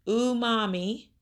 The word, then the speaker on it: umami: It's umami, if I'm saying that right, U-M-A-M-I.